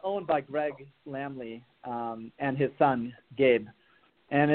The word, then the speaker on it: Laemmle: from the Lamley Theater chain.